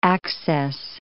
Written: access

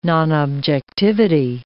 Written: nonobjectivity

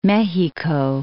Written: Mejico

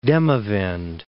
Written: Demavend

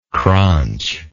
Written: cranch

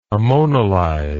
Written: ammonolyze

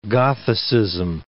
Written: Gothicism

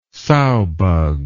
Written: sowbug

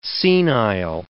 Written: senile

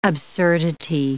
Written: absurdity

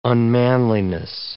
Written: unmanliness

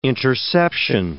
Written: interception

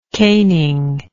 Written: caning

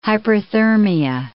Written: hyperthermia

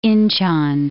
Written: Inchon

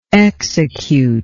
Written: executer

